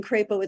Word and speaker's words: Crapo: But as a result, on his campaign signs, according to his staff, he has something called a macron, which is a little line that says it's a long A in Crapo. It's not a short A as in apple.